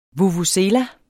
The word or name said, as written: vuvuzela